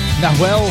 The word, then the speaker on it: Nahuel: Nahuel, I hope I pronounced that right, N-A-H-U-E-L.